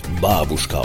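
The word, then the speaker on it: Babushka (бабушка): Babushka, im Russischen wird es eigentlich Babushka ausgesprochen und bezeichnet ein Großmütterchen.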